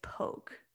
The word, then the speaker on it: Lompoc: I think it's Lompoc. – It's spelled that way, but I think it's Poke.